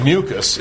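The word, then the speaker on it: mucous: By the way, the adjective mucus is spelled O-U-S. The noun, the stuff, is also pronounced mucus, but it's M-U-C-U-S.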